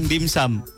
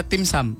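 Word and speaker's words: dim sum: Orang banyak yang bilang dimsum. – Uh. Ah. Sebenarnya bacanya timsum. Timsum.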